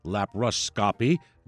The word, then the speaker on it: laparoscopy: Dr. Bogardis specializes in general surgery with a focus on robotic surgery and complex laparoscopy. Laparoscopy is the actual term. I added an extra syllable.